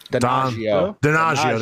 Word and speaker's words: Donaggio: All right, so music by Pino Donaggio. Am I saying that right? – Donaggio. – There we go.